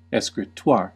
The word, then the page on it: escritoire: British English; /ˌɛskriˈtwɑː/; American English; /ˌɛskrɪˈtwɑːr/